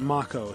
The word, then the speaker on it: Mako: They actually pronounce it Mako, so I should as well.